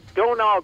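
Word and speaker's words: Domhnall: Donal Gleason is how you pronounce it. It's weird because there's an H and an M in there.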